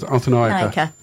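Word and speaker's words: Arthanayake: I go with Arthanake. – Arthanake. I knew that.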